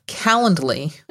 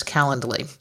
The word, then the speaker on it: Calendly: The three that I want to talk about are Schedule Once, Calendly, and I'm not sure I'm pronouncing that's right, but it's C-A-L-E-N-D-L-Y. So it's like calendar, but with a D-L-Y at the end of it.